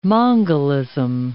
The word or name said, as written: mongolism